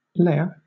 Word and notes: lair: British English

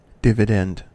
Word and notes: dividend: American English

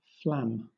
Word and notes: flam: British English

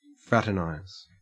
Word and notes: fraternize: Australian English

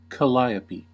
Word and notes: calliope: American English

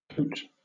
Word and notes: cote: British English

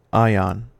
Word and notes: ion: American English

